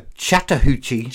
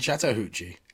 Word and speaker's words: Chattahoochee: And in the Chattahoochee... – Ooh, nice name. – No, Chattahoochee. – Chattahoochee. Even better, extra syllable there.